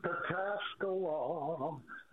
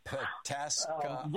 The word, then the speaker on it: Pataskala: Tell me how to say this. – Pataskala. – Pataskala.